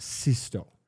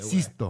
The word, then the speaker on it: Sixto: But if you take the record out and examine the sleeve, the artist's name is Sixto Rodriguez, spelled S-I-X-T-O. – Okay. – Pronounced Sisto, by the Sisto.